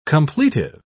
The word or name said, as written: completive